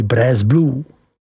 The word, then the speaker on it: Bresse Bleu: ale také výrobou prvotřídního sýra Bresse Bleu, vyslovuj Bres Blu.